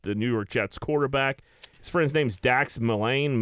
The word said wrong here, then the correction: Milne: Milane, I don't know how the fuck you pronounce his last name. – Dax Milne. – Milne.